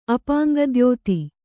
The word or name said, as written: ಅಪಾಂಗದ್ಯೋತಿ